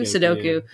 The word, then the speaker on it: Sudoku: I'm a kid at heart, and I'm not good at math, so I don't do Sudoku. I don't even think I'm saying that right. Is it Sudoku? Yeah, Sudoku.